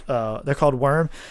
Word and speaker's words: WVRM: They're called Worm. If you look them up on Facebook or Spotify, you have to type WVRM, but you know it's pronounced Worm.